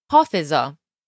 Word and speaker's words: حافظا: He starts by saying Hafiza, so he's addressing himself, Hafiza. So obviously this has the ah sound at the end, which serves as a kind of emphasis, like saying, oh, Hafiz, Hafiza.